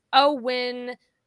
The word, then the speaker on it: OWYN: But anyway, OWYN pronounced O-win. Stands for only what you need.